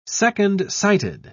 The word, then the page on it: second-sighted: British English